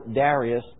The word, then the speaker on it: Darius: And this is what we read, Daniel chapter 9, verse 1, "In the first year of Darius," or Darius, depending on which syllable you put the accent,